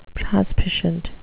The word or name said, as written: prospicient